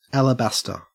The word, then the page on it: alabaster: Australian English